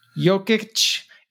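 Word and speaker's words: Jokić: Before we get into to work stuff, tell me about this Jocic guy. Am I saying that right? – Jokic.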